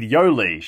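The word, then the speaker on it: Yolei: It said Yoli. She pronounced her name to rhyme with Go See.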